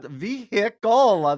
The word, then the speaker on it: vehicle: Like that vehicle, that's the way it looks. And then I looked it up online and it's like, you don't pronounce the H.